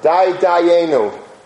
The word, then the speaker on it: Dayenu: The way that we sing it is to say over Dai Dayenu. Dai Dayenu. Dayenu, Dayenu, but it's there's an emphasis on the Dai. On the fact that it's enough.